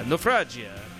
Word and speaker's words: Naufragia: They're like, well, I guess that's how you pronounce it. I was like, yeah, Naufragia. You got to be Italian for that one, I guess.